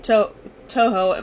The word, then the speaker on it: Toho: Toho, I hope I'm saying that right.